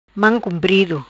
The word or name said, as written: Mam cumprido